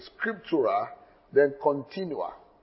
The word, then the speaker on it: scriptura continua: Scriptura is spelled S-C-R-I-P-T-U-I-R-A. Scriptura, S-C-R-I-P-T-U-R-A. Scriptura, then Continua. C-O-N-T-I-N-U-A.